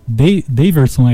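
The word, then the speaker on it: Deyverson: E se no início do ano foi Breno Lopes, desta vez foi o De Daverson, é assim que pronuncia, né? Exatamente.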